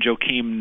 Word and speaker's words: Joakim: Well, Joakim, as they pronounce his name, Joakim Noah, is a fascinating, cosmopolitan kind of citizen of the world guy.